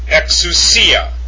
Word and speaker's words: exousia: Exousia, exousia, and that is the Koine Greek form for authority.